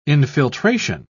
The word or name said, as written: infiltration